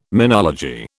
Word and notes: menology: British English

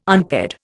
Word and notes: unpaired: Indian

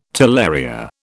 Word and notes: talaria: British English

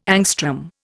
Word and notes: angstrom: Indian

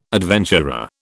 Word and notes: adventurer: British English

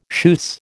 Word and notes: schuss: American English

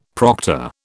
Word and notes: proctor: British English

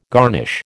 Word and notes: garnish: American English

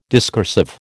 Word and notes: discursive: American English

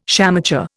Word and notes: shamateur: Indian

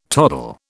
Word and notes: toddle: British English

